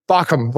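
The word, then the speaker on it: Baucham: There's a preacher, his name is Vori, and I never know how to say his last name, Bachum. Bachum? No, I'm just kidding. It's a Bachum, Vori Bachum.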